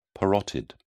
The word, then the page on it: parotid: British English